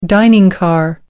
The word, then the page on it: dining car: American English